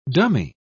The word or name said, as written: dummy